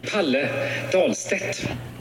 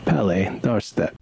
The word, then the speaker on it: Palle Dahlstedt: I know very clearly that's Palle. And his last name is D-A-H-L-S-T-E-D-T.